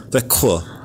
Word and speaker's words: Växjö: Växjö. There's no K in this, you guys. It's spelled V-A-X-J-O.